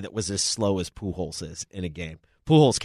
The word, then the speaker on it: Pujols: Pujols was okay. Did I say that right? – Yeah, Pujols. Yeah.